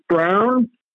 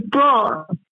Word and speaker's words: Braun: his family's been trying to convince people that his name is Brown, but when he was running for the Senate, he pronounced it correctly, which is Braun.